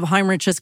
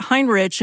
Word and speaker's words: Heinrich: And I've had a couple of inquiries about his name, which is spelled H-E-I-N-R-I-C-H. And I pronounce it Heinrich, and people have said, it's Heinrich. It's not. It's Heinrich.